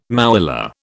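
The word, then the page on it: mouillé: British English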